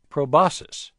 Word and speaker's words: proboscis: The word is proboscis. The C is silent.